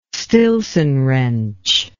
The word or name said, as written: stillson wrench